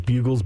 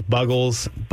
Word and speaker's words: Buggles: You know, I spent way too much time looking up how to pronounce it, whether it was Bugles, Buggles, Bungles. I determined it was Buggles.